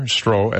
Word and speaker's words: Stroh: Jan Stroh, spelled like the beer, Stroh, S-T-R-O-H,